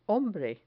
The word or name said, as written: hombre